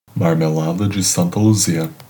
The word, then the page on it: Marmelada de Santa Luzia: Brazilian Portuguese